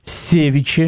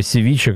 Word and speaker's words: севиче: Севиче или севиче, кто там? – Севиче, наверное. – Нет-нет, ударение ставится и так, и сяк.